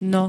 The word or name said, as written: no